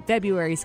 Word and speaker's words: February: As for why we don't bother pronouncing that R and make it February, the answer is basically laziness, or because it's like hard. We have a tough time when there's two R's right close to each other, so we drop one.